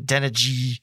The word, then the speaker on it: Adeniji: I swear, Chris Collinsworth couldn't say Adeniji's name right. Adeniji. It's like, no, that's not how it's said, unless Dan Hoare got it wrong. Because, you know, I listen to the games on the radio. I'm pretty sure it's Adeniji.